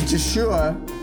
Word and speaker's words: Yeshua: His name is Yeshua. The Y is pronounced with a J.